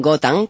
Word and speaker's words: Gotham: Exactamente, que se pronuncia Gotham.